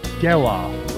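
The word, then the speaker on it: Gelof: Breaking shortstop records, Bobby Witt Jr. It's Geloff. Like sell-off, but Geloff. I keep emphasizing the off, but you emphasize the gel.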